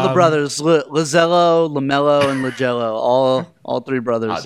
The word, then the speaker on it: LiAngelo: Jello, is that how they pronounce his name? LiAngelo? LiAngelo? – LiAngelo.